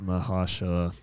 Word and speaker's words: Mahershala: Mahershala Ali.